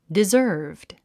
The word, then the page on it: deserved: dizə́ː r vd; ディザーブド